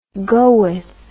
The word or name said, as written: goeth